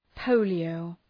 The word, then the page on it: polio: ‘pəʋlı,əʋ